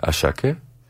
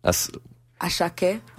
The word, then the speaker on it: Asake: Move by Asake. Mm-hmm. Did I pronounce that right? – No. – Asake? – Asake.